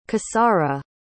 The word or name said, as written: cassara